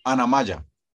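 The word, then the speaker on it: Annamaya: como, como Ana Moya. – El Maya. – ¿Cómo se dice? Ana Maya. – Ana Maya.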